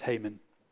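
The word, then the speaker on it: Heman: Actually, it's spelled He-Man, but it's pronounced Haman.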